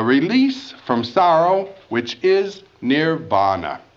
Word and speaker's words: nirvana: Beyond, near, N-I-R, vana, V, long A, N with a dot under it, A. Nirvana, beyond winds.